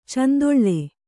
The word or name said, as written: ಚೆಂದುಳ್ಳೆ